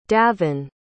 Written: davan

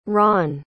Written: ronne

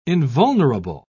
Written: invulnerable

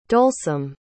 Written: dolesome

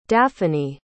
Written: daphany

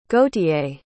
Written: gautier

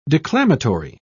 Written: declamatory

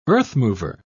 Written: earthmover

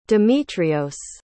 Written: dimitrios

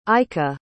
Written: aika

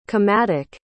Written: comatic

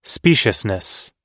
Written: speciousness

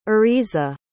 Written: ariza

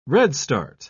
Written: redstart

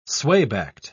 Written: swaybacked

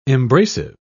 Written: embracive

